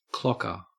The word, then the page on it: clocker: Australian English